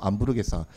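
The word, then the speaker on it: hamburguesa: 그래서 햄버거를 안부르게사라고 해요. 그 남미에서는 그래서 H가 묵음이라서 안부르게사.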